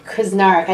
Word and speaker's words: Krznaric: Krznaric.